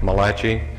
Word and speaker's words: Migliaccio: My name is Ralph Malachi. It's spelled M-I-G-L-I-A-C-C-I-O. And that is not Dr. Malachi by any stretch of the imagination.